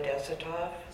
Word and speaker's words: Desatoff: My name is Lila Desatoff. Last name is spelled D-E-S-A-T-O-F-F.